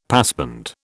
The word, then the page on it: passband: British English